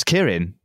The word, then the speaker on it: Keirin: Well, I mean, I've already I'm hearing the way you pronounce Keirin. You're doing it properly. So give me a bit of some notes on how to say it properly. – Sure. Well, you're not saying it incorrectly either.